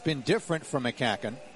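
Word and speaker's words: McCaughan: All right, Darren McCacken, spelling it M small c, capital C, A-U-G-H-A-N. It looks like McGoggin, but it's pronounced McCacken.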